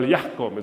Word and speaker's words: yahkum: The Quran just said, the Quran in the 7th century says, people of the gospel, Christians, you judge, it's a command, well, yahkum is how you say it in Arabic.